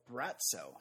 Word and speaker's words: Braco: His third consonant is pronounced with a TS sound. Oh, Bratso. He's called Bratso.